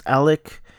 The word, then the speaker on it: Alex: You know, at the end, this guy, Alec, it's spelled Alex, but it's pronounced Alec.